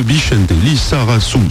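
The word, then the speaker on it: Bixente Lizarazu: Plus de signatures avec l'arrivée de Bichente Lissarassou, c'est comme ça que ça se prononce, il me l'a confirmé, chaque mercredi à 18h pour 60 minutes en liberté et quelques rendez-vous exceptionnels dans l'année en compagnie de Bernard Montiel.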